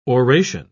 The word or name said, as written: oration